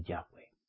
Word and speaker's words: Yahweh: Pero la orden de nuestro creador es que pronunciemos el nombre Yahweh.